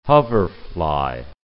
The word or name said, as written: hoverfly